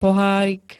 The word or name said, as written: pohárik